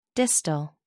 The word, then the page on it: distal: American English